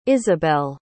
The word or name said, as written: isabelle